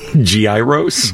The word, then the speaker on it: gyros: The actual correct pronunciation, it's like a soft G sound in the beginning. Gyros. – Gyros. – Like G, like G-Y, almost like G-Y. Gyros. – I don't even hear the G. I hear Y-E-E-R-O-S.